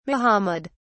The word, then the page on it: mohammed: British English